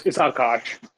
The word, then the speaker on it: Akash: So it's Akash, the right way to say it.